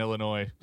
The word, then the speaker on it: Illinois: Yeti here is giving me shit for saying the S in Illinois. I know there's no S in there.